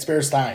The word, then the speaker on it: Berenstain: Yeah, it's Berenstain, and it's written that way, and everyone says it the wrong way, and no one knows why.